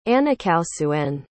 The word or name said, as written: anakausuen